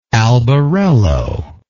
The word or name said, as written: albarello